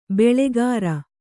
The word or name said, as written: ಬೊಳ್ಳುಗರೆ